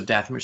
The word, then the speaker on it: Dathomir: Like, you go to, I'm going to say it wrong now, all of a sudden, Dathomir? Is that right? – No, that's right. – Dathomir. And so Dathomir, so you, that's one of the options.